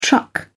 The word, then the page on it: truck: British English